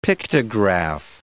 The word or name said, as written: pictograph